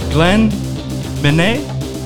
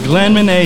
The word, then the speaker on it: Glenn Midnet: I want to introduce to you, you guys and you listeners, Glenn Minet, did I say that right? – Yes, sir. – All right, we got Glenn Minet here.